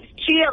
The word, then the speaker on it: Chia: Is it Chia or Chaya? How are you pronouncing this? – It's Chia, like the Chia Pet.